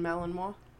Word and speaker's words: Malinois: A Belgian Malinois.